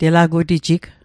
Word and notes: telagutijig: Mi'gmaq

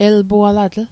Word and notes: elpualatl: el'pualatl; Mi'gmaq

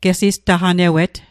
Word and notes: gesistaqnewet: Mi'gmaq

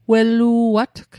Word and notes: wellu'atg: Mi'gmaq

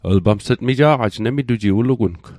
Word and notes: mija'qaj: Mi'gmaq

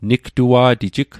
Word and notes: nigtua'tijig: Mi'gmaq

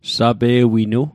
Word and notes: sape'winu: Mi'gmaq

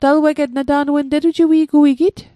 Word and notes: talueget: Mi'gmaq

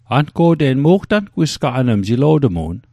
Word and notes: angwisgaqan: Mi'gmaq

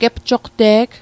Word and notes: gepjoqte'g: Mi'gmaq

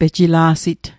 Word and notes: pejila'sit: Mi'gmaq

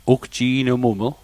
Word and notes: ugji'nmuml: Mi'gmaq

